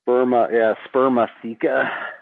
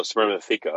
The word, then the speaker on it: spermatheca: And that's how do you say that word again? Spermatheca or theca or what? – Spermatheca. – And the last four letters are what? F or S or? – Theca, like T-H-E-C-A. – Oh, spermatheca. Okay.